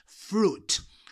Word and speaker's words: fruit: So is fruit. I want some more fruit. I don't like that fruit. Fruit. Suit. No se dice la I, ¿vale?